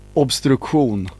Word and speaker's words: Obstruktion: This band is called Obstruktion. I don't know. How do you say it in Swedish? – Obstruktion. – Obstruktion.